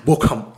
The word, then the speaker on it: Bochum: But I just want to talk about this one point where this weekend Bayern played against Bochum or Bochum. I'm guessing I'm pronouncing it horribly wrong. – Bochum. – Yeah, perfect. Bayern scored 7 goals.